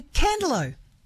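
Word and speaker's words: Candelo: Candaloo, a place I always pronounce correctly.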